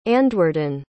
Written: andwyrdan

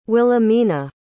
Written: willamena

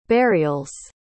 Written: burials

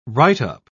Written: write-up

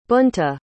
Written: Bunta

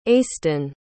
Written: aiston

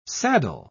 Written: saddle

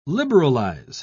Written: liberalize

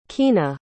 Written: keena

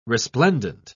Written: resplendent

